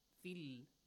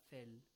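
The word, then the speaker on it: في: That kasra is pronounced with an E sound this way. E. Fil, not fel. Fil. Fe, fi. Fe, fi. See? They are different.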